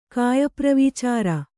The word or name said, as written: ಕಾಯಪ್ರವಿಚಾರ